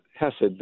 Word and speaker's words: hesed: Am I saying it right? Hesed or Hesed? – It's Hesed. The stress is on the first syllable.